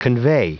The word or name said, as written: convey